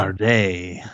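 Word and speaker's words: Karrde: Was it Talon Card? Was that his name? I probably said that wrong. Yeah, this guy. Talon Card. Cared. Card. Anytime. – Carday.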